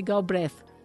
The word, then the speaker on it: Galbraith: É, para quem não, sabe, é Nancy Galbraith que escreve, né? Com TH no final, mas pronuncia Nancy Galbraith.